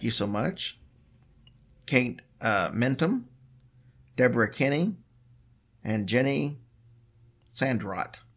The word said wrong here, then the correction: Sandercott: I said that wrong. It's Sandercott.